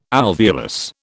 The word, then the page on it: alveolus: British English